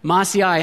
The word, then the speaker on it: Maaseiah: Mattathiah, Shema, Ananias, Uriah, Hilkiah, and Maaseiah. That's how it's pronounced. I had to work on that one all week, and I still messed it up. A lot of vowels in that name. Maaseiah.